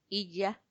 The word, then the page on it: iyya: Macuiltianguis